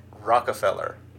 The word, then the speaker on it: Rockefeller: You said Rockefeller. – What is it? – Rockefeller. – Rockefeller. – Yeah, there's no R after the rock.